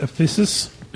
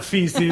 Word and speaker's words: Ephesus: Enjoy lunch in the heart of the Ephesus. – Pretty sure it's not feces.